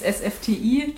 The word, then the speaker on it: SFTI: Also Künstlernamen würde ich es eher nennen und meiner ist SFTI. Das ist, wenn man es kurz spricht, so wie Steffi, also wie mein Name, aber heißt ausgesprochen Searching for the Invisible.